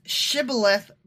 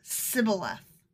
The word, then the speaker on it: Shibboleth: Because it's Shibboleth versus Sibboleth. – Okay, but still. – So they couldn't say their SHs right.